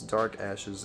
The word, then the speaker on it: DVRKVSHES: But anyway, it's Dark Ashes. It's spelled all caps DVRKVSHES. I know V usually sounds like V, but in this case it's ARK.